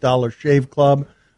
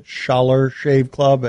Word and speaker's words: Dollar Shave Club: Many of you are making fun of it, me because I mispronounced Dollar Shave Club. I guess I called it Schaller Shave Club, and people are pointing out a hypocrisy.